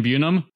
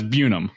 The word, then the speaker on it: Bunum: This is from 2003, so I guess their pronunciation may have changed, but it says recently Murray and Bunum pronounced to rhyme with Unum. So that's Bunum.